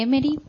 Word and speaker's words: Emmerich: Y compartiendo con una hermanita, veíamos cómo en el libro de Ana Catalina Emmerich, ¿no? ¿Lo he dicho bien? Pues, ¿qué pasaba, Patrick? – La Santa Ana Catalina de Emmerich tuvo visiones de toda la vida del Señor.